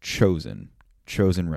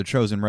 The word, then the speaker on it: Chosin: So one thing comes to another, and there are three American divisions being encircled around a reservoir that was mispronounced by the Japanese from the original Korean name written down on a map, and then the Japanese name, which is a mispronunciation of the Korean name, was mispronounced by the Americans, in which it was referred to as Chosin Reservoir.